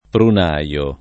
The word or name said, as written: prunaio